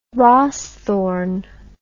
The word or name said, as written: rawsthorn